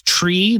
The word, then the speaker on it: trie: My biggest pet peeve is tree, that's spelled T-R-I-E.